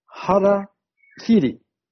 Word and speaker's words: harakiri: But the Japanese word is harakiri.